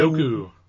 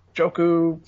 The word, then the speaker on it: Njoku: Njoku. Njoku.